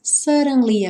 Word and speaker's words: suddenly: Why do birds suddenly appear? Suddenly. Aqui, esses dois D's vão ter um som de R, R, de R, como ali na legenda em azul.